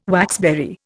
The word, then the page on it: waxberry: Indian